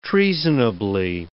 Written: treasonably

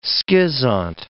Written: schizont

